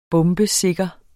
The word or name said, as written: bombesikker